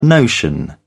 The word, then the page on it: notion: British English